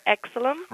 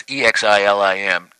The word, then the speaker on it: Exilim: Exilim. – Right, exactly. Okay, and that's Exilim.